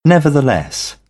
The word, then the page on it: nevertheless: British English; ə